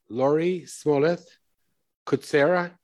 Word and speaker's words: Laurie Smollett Kutscera: Laurie Smollett, Kutscera.